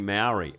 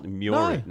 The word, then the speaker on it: Māori: But like I was saying Maori, and I don't know, is that an insult? Māori? No. – Maori. – It's Māori.